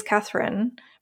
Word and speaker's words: Catherine: So my second name really is Catherine, but it's not spelt that way.